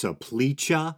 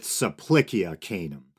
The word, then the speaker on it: Supplicia canum: So the Supplicia canum, I've also heard it pronounced Supplicia, which sounds more like a modern Italian pronunciation, and I think it depends on the kind of Latin you're going with, but as far as I can tell, in classical Latin, the C should always be pronounced with a hard sound, so I'm going with Supplicia canum.